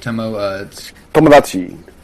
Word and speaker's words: Tomodachi: Tomodachi.